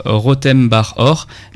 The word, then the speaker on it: Rotem Bar Or: Et ça s'écrit comment, Rotem Bar Or R-O-T-E-M, espace, bar comme un bar et or comme de l'or.